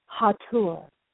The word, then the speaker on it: Hathor: And before I do so, however, I want to just explain that my pronunciation of Hathor has to do with the fact that some of my teachers of ancient Egyptian teachings pronounce H-A-T-H-O-R, Hathor, because in the hieroglyph that is the depiction of her name, you'll see the falcon, which is Horus, and Hathor was known as the house of Horus.